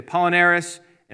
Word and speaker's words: Apollinaris: I should say Apollinaris and Apollinarius, same person, just two different ways to pronounce their name.